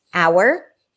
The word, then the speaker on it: hour: The only time that it's dropped is like in the five words, hour, heir, heir to the throne, herbs in American English, honor, and honest.